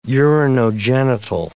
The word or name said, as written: urinogenital